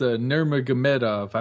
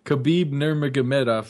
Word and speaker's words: Nurmagomedov: Yes, he could, and it's Nurmagomedov. I finally learned how to say it. – Say it again. – Khabib Nurmagomedov.